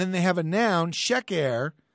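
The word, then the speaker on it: sheker (שֵׁכָר): but it's pronounced sheker, sheker.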